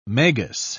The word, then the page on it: magus: British English